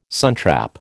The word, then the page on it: suntrap: American English